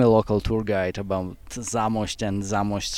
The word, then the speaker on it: Zamość: But we've come to the beautiful city of, my last chance to mispronounce something, Zamość. – It's Zamość.